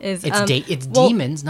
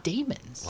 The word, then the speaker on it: daemons: So demons, not daemons. – Wow. Oh, no. Anyway, so... – I heard Philip Pullman, that's how he That's how it sounded in his head as he wrote it. Demons. Okay. But it's spelled D A E M O N, Damon. So that's why we read it that way.